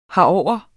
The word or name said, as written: herover